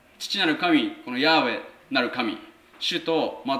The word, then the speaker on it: ヤーウェ: でおそらくはっきりとしてないんですけども、ヤーウェっていうふうに言われるのが正しいんじゃないか、正しい発音じゃないかというふうに一般的に言われています。中にはエホバっていうふうに発音する人たちもいます。